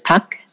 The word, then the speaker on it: Park: My maiden name is Park, or the way we would pronounce it is Pak.